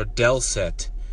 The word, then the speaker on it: dulcet: So the word of the day is Dulcet, or Dulcet. It's pronounced kind of like Spanish, like candy. Dulce. It's like D-U-L-C-E-T, but it's this, the pronunciation looks like Dulcet.